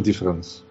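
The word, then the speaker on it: différance: По-французски два слова «различие» и «различание» произносятся одинаково. То есть в одном случае это «difference», и в другом случае тоже «difference».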